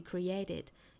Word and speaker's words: create: 这个单词重读在第二个音节 create, 之后加 ed 的发音呢，要加一个 a 的元音在里头